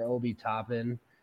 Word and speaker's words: Obi Toppin: They just traded for Obi Toppin.